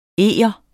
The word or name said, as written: EGERNE